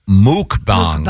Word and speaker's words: mukbang: No, the word, and it's pronounced mukbang. – Mukbangs. – Mukbang. – Mukbang. – Yeah. It's actually pronounced muk, Is it muk? – Mukbangs. – Yep. Oh, I thought it was mukbang. – The pronouncer here is M-O-O-K. – Oh, okay. I've always heard muk. The people who do it, I've heard say muk. – It's spelled M-U-K-B-A-N-G.